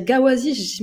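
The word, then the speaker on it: ghawazi: Faut dire gawazi. mais attends, va, va demander à un prof d'arabe, d'accord Comment ça se prononce C'est comme le Maghreb. Euh, le Maghreb, c'est Maghreb, euh, Rawazi, voilà, il y a le Ra, vous savez le prononcer dans les pays latins.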